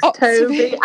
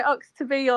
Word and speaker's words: Oxtoby: Oxtoby. I say Oxtoby or not to be because that's easy to remember, but literally it's the worst surname in the history of the world.